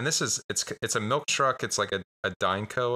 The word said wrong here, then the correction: Divco: or whatever milk truck. I'm probably saying that wrong. – Yeah, Divco.